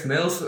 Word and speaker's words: mails: Blijkbaar is mails, uit, wordt uitgesproken als mails.